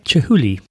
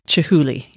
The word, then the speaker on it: Chihuly: And then we have the Chiluli. Did I say that right? Chihuly. Chihuly. Yes, we have one of those.